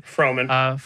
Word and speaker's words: Fremen: But at this point, I brought up when they go to meet the Fremen, right? The Fremen is how to say it. – Fremen.